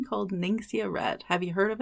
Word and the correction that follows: Ningxia: I don't know that I'm pronouncing that right. It is Ningxia.